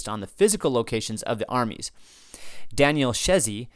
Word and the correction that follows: Szechi: sorry, I mispronounced that. Daniel Seshi, S-Z-E-C-H-I, Seshi, of course, has written some great stuff on the Jacobites.